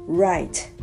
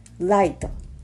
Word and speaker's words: right: 右という単語 right right これを light light っていう風に発音してしまう